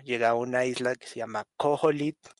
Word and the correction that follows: Koholint: Koholit, no sé cómo se pronuncia. – Koholint Island. Koholint Island.